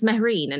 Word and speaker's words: Mehreen: She was Amberine, like a tambourine, and I was Marine, like a submarine, trying to make it easier for everyone, like anglicizing our names and trying to make it easier for everyone to pronounce. And it's literally over the last less than a couple of years where I thought, I'm not doing it anymore. My name's Mehreen and people need to try and say it right.